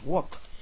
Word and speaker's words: walk: Walk, walk, como lo de los chinos, walk. Andar, pasear, ok, la L no se pronuncia, walk.